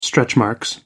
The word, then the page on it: stretchmarks: American English